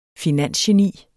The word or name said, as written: finansgeni